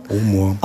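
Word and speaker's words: Bowmore: Und dann können Sie auch dabei sein, wenn zum Beispiel ein alter Bomo, wird der so ausgesprochen? – Bomo.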